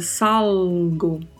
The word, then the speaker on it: salgo: E a pronúncia é com este L tocando o céu da boca, salgo.